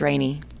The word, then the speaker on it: Rainee: My middle name is Rainey. – Really? – R-A-I-N-E-E. – Mm-hmm. How do you spell it again? – R-A-I-N-E-E.